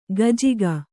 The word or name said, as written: ಗಜ್ಜಿಗ